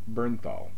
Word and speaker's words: Bernthal: Bernthal.